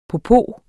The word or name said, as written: popo